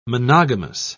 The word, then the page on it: monogamous: British English